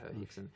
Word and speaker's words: Rickson: This guy, his name is Rickson Gracie with an R, but if you pronounce Hickson.